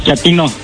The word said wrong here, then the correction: chatino: No, chatino. – Es como chato, ¿verdad? Chatino. – Sí, azunero como chato, chatino.